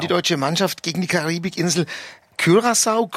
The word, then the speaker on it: Curaçao: Wir sagen Curaçao, wir haben ja in der ARD ein wunderbares Tool, eine Aussprache-Datenbank, wo Expertinnen und Experten die Köpfe zusammengesteckt haben. Da steht ein leichtes Ü drin, deswegen haben wir uns jetzt darauf geeinigt.